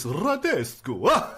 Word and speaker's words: Radescu: Radescu, I'm probably saying it wrong, Radescu, removed. – I think it's, you got to do the, it's Radescu. – Radescu. – That's better.